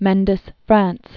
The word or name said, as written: Mendes-France